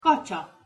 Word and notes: kacsa: Hungarian

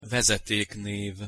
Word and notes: vezeteknev: Hungarian

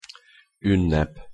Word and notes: unnep: Hungarian

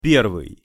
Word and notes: pervyj: Russian

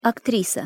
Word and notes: aktrisa: Russian

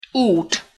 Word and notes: ut: Hungarian

